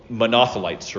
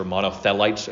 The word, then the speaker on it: Monothelites: It condemned the Monothelites, or Monothelites, again, depending on how you pronounce it.